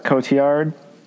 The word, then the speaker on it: Cotillard: Marion uh, Cotillard.